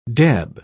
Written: deb

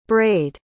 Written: brayed

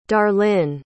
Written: darlyne

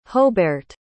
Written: hohberht

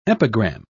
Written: epigram

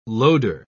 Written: loader

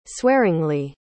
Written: swearingly

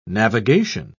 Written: navigation

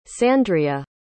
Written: sandrea

